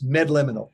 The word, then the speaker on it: Medliminal: When I started with the company, Kyle, it took me 3 weeks to figure out how to pronounce Medliminal. Now I got it. It's Medliminal. M-E-D-L-I-M-I-N-A-L.